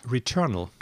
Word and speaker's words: Returnal: Ähm, und zwar machen ja äh, Hausmark, wie spricht man es eigentlich aus? Returnal. Genau.